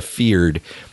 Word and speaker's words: Fyrd: It's called Raise the Feared, and that is spelled F-Y-R-D.